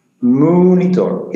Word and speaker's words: Munitor: I Munitor, like the moo of a cow? – Munitor, yes.